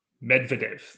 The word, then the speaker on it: Medvedev: Medvedev, that's right. – No, I prefer Medvedev.